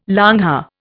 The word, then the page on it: longhair: Indian